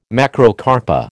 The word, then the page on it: Macrocarpa: American English